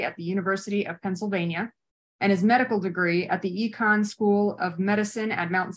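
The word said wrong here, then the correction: Icahn: Did I say that correctly? Is it Econ? – Icon, okay.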